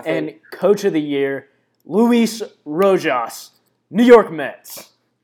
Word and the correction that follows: Rojas: Hey, it's pronounced Rojas. Get out of here. – New York Mets. – Okay, first rule of Spanish. A J is pronounced with the sound.